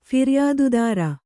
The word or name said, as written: ಫಿರ್ಯಾದುದಾರ